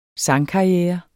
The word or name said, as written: sangkarriere